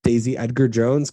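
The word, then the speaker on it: Daisy Edgar-Jones: Lauren is the screenwriter and executive producer, one of the executive producers of Fresh, starring Sebastian Stan and Daisy Edgar Jones.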